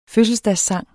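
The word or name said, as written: fødselsdagssang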